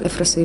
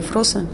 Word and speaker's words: Эфроса: Клим – ученик Эфроса или Эфроса, по-разному говорят, и Васильева.